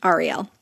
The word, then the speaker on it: Ariel: I'm sorry, I was mispronouncing it earlier. Ariel.